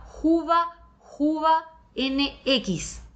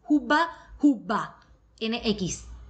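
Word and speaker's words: Hubba Hubba NX: La más famosa entre viajeros extranjeros es la marca MSR, que es una marca gringa, específicamente el modelo Juba Juba NX. Se escribe con H y doble dos veces las B largas. Juba Juba NX dos.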